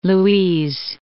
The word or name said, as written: Louise, Lake